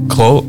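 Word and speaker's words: grød: You stick your tongue out. Exactly. That's what you do. – And that's, what I thought was fascinating. – Klød.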